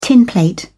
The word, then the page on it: tinplate: British English